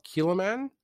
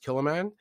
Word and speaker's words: Kilchoman: Kiloman and it's actually spelled Kilchoman, but it's pronounced Kiloman.